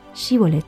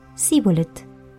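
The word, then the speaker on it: Shibboleth: Para distinguirlos y antes que el soldado pudiera pasar, se le obligaba a decir una palabra: Shibolet. Si era efrateo, no podía pronunciar la palabra correctamente y decía Sibolet.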